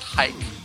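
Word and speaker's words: Huyck: So, his last name is pronounced Hike.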